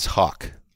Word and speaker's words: Haack: I just don't see how H-A-A-C-K is Hawk. – It's not. I'm not suggesting that if you're just going to look at the way it's spelled, I wouldn't come to the same conclusion, but it confused me, so I went and looked it up, and I've been told that it's Hawk.